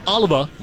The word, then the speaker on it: Oliva: I feel like when I look at Jared's last name, it's spelled O-L-I-V-A. I feel like I'm saying it with a Boston accent.